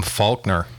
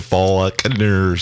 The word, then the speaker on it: Faulkner: This week's quip or quote is from William Faulkner. – I don't think you pronounce the L.